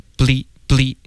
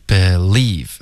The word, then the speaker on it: believe: Okay, believe 别发成 bleep. Okay, 这是一个音节，我们要 believe 两个音节。